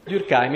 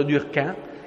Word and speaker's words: Durkheim: Durkheim, il cognome è tedesco, la pronuncia è francese, tant'è vero che un mio collega dice che bisognerebbe pronunciarlo Durkheim, perché lui era dell'Alsazia-Lorena, e quindi non so se conoscete, vi ricordate la storia dell'Alsazia-Lorena.